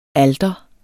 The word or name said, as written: alter